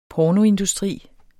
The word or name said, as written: pornoindustri